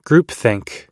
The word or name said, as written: groupthink